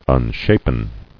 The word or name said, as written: Unshapen